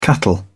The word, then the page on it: cattle: British English